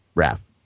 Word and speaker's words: Raf: People say my name as such. Hey, Raphael, where does the I come from? Hey, Raphael, are you dyslexic? Guess what? I will just have you say my name, Raf.